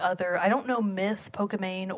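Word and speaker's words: Pokimane: Nominees are Dr. Lupo, Myth, Ninja, Pokemon? Pokemani? – It's Pokemane.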